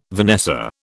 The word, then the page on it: vanessa: British English